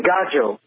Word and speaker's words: gajo: They say Gorgio for a non-Gypsy, which is I grew up saying Gajo, G A J O, And they say Gorgio, and I don't know where that comes from, but yeah.